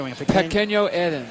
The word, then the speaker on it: Pequeño Eden: Pequeño Eden.